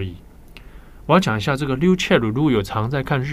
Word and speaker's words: ryuchell: 好，那在日文发音里面会直接叫他 ryuchell 啊，ryuchell。